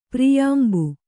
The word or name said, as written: ಪ್ರಿಯಾಂಬು